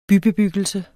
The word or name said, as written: bybebyggelse